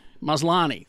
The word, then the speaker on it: Maslany: Tatiana Maslany. Maslany.